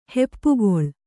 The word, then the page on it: ಹೆಪ್ಪುಗೊಳ್: heppugoḷ